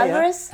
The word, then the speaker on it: Alvarez: Alvarez. – Yeah, Alvarez.